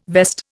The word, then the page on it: vest: Indian